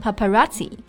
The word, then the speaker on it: paparazzi: 但是听发音啊，就是个外来词，因为它的末尾拼写呢是 double z i，但是发的却是起这个音 paparazzi。